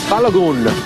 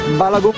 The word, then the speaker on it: Balogun: soccer star Florin Bala. – Florian Balogun. Balogun. – Sports. There you go. – Yeah. – Sorry, say again. – Sports. Balogun.